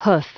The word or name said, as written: hoof